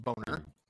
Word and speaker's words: Bonner: Oh yeah, there's the classic boner, B-O-N-N-E-R. – Sure. – But not like boner or something. It's literally pronounced boner.